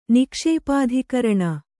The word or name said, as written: ನಿಕ್ಷೇಪಾಧಿಕರಣ